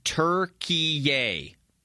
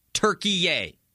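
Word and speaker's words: Türkiye: I looked it up last night, and the way you pronounce it is Turkey-ia. Turkey-ia. Turkey-ia. Not Turkey.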